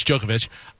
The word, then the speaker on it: Djokovic: It's spelled really weird, but it's Djokovic, is sorry after he intentionally smacked a tennis ball in frustration at the U.S.